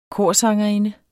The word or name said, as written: korsangerinde